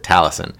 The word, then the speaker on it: Taliesin: Taliesin? That's it. Taliesin.